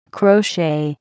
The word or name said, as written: crochet